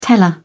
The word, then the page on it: teller: British English